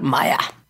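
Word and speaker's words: Meyer: de nombre Dmitri Ivanovich Mendeleev y Julius Lothar Maya. – Maya, porque uno, Mendeleev, era ruso y Maya, como su nombre lo indica, era alemán.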